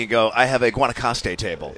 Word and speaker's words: Guanacaste: Guanacaste, yes, sir. And everybody mispronounces it.